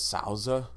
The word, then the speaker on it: Souther: The funniest thing is characters like Souther, which in Japan they pronounce it as Souza.